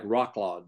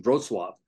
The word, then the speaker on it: Wrocław: It looks like Rocklaw, Wroclaw, but it's pronounced Wrocław.